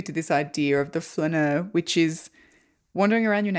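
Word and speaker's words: flâneur: a flaneur.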